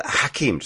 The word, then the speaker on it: Hakeem: Sorry, did I pronounce that correctly? Yes. – Right. – So Hakeem, just to make sure I say it correctly.